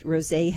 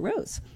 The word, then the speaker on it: rosé: And so rose and rosé are spelled the same, except rosé has the accent aigu on the end, so you say rosé instead of rose, which, for whatever good that is, that's I just thought interesting.